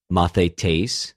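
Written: μαθητής

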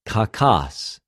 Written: κακος

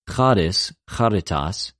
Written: χαρις,χαριτος

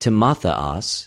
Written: Τιμοθεος

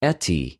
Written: ἐτι

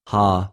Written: ὁ

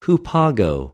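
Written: ὑπάγω

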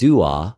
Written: δυο

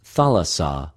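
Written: θαλασσα

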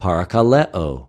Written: παρακαλεω

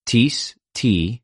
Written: τις

